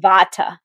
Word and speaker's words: vata: And we pronounce it vata here in the West.